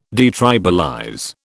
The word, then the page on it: detribalize: British English